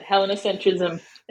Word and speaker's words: Hellenocentrism: So we used to play around with different words, but Hellenocentrism is how I pronounce it, this idea of putting Greece at the center.